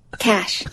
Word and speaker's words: cache: Now it's spelled C A C H E. – Cash. – Thank you. It sounds like she's sneezing. – Cash.